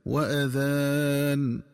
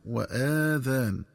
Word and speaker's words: وأذان: ينبغي التنبه للنطق الصحيح لكلمة وأذان، فتنطق بهمزة مفتوحة دون مد هكذا وأذان بمعنى وإعلام، ومن الخطأ قراءتها بفتح الهمزة وإشباع فتحتها حتى يتولد منها ألف فتصير وآذان، والآذان جمع أذن.